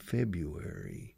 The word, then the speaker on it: February: Pero escuchad la pronunciación mía. Febu, bu como viuda. Viuda negra, bu. Febu, febu, y luego llevar puesto. Where. February. Wary, con Y al final.